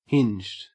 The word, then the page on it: hinged: Australian English